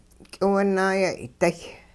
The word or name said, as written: k'iwunaayay'day